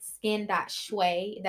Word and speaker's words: shui: I'm at skin.shui. That's S-H-U-I, like feng shui, but for your skin.